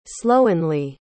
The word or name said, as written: slowenly